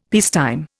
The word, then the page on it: peacetime: Indian